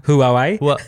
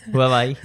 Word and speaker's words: Huawei: Jag säger Hawaii för jag kan inte uttala. Hawaii. Huawei. – Huawei, ja. Precis.